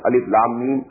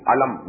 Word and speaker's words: alif lam mim: These are pronounced with separately, and so they are called, they are the broken into pieces. Not pronounced as alif lam mim alam, no. Alif lam mim. Alif lam ra alar, no.